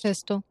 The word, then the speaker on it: pestle: And she pulls out a little mortar and pestle. Is that how it's pronounced? – Pistol.